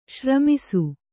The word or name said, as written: ಶ್ರಮಿಸು